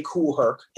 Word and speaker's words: Kool Herc: DJ Kool Herc, his name rhymes with schoolwork.